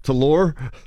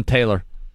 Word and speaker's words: Taylor: T-A-R-O-D. – Is it also pronounced Talor? – I don't believe so. I believe that's a full-on Taylor.